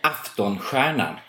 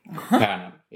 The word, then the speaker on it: Aftonstjärnan: Yes, how do you actually pronounce the name of the theater? – Aftonstjärnan. It means the evening star. – Aftonstjärnan? Mm, Stjärnan. Yes, that's right.